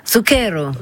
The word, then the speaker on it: Zucchero: Comment vous prononcez déjà, vous? – Zucchero. – Zucchero, ah oui. Quand je dis Zucchero, Zucchero, ça a quand même beaucoup plus de panache.